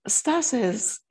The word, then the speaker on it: Stasa: Is it Stasa? – Stasa, because American people probably say Stace, don't they? – And that's okay too.